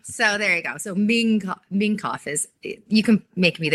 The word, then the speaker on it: Mingkoff: They spelled it M-I-N-G-K-O-F-F. So I guess it was spelled wrong. But that sounds like the Chinese version, Ming Koff, right? – It's like Ming Koff.